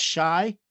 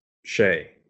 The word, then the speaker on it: Shai: Is it pronounced shy? – Shay.